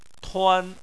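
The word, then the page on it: tuan: tone 1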